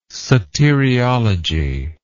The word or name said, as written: soteriology